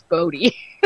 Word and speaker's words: Bodhi: I thought it was called Body Troll because that's how it's spelled, but I think his name's Bodhi. That's how you're supposed to say it.